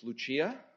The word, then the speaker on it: Lucia: It's Lucia, with a ch, and whether it's Italian or Latin, I don't think it's a Spanish name, so that's good.